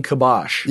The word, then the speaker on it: kibosh: So what do you mean when you say reclaim kibosh? – Yeah. – Am I saying that right? – Yeah, you are.